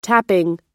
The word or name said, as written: tapping